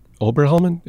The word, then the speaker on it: Oberhelman: Because Doug Oberhelman, is that how you pronounce his last name? Doug Oberhelman, who's the CEO? – I believe so, yes.